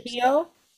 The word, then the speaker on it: Trujillo: I can't remember how the Trujillo, but I can't remember how they pronounce Trujillo. Double L is a Y sound and a J is a H sound.